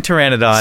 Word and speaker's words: pteranodon: Yeah, a very important pteranodon. – Silent P, everyone.